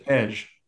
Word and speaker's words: Tej: No, back to T-Dub's point about Trej. And is that how you say it? I always screw it up. Is that right? – Tej. – T-E-J. – Tej. Like if you mispronounce Pez. Pej. Tej.